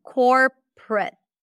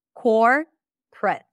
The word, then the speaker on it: corporate: Listen, corporate. There are two syllables in this word. Core, prut, prut, prut.